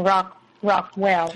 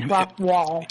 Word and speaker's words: Rockwall: I think you're saying Rockwell. It's Rockwall. – It's like a wall of rock.